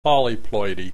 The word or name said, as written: polyploidy